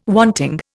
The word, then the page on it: wanting: Indian